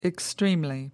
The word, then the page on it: extremely: American English